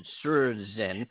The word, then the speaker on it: Surrozen: You know, there's a couple of – I found a company just in front of research called – it's spelled S-U-R-R-O-Z-E-N, Surzen.